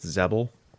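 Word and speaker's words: Xebel: It's X-E-B-E-L. it's not a real name. Say it however you want. I think in the Aquaman movie, they pronounced it Zebel. So I guess that is the correct pronunciation, is Zebel.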